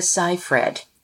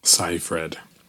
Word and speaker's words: Seyfried: Remember, when you get on ABC Live and millions of people are watching, it's Amanda Seyfried. – Seyfried. – Write it on your hand. – Yeah, I'm like Seyfried.